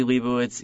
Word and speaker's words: Lebowicz: On Twitter, I'm at Eli Lebowicz, @ELILEBOWICZ.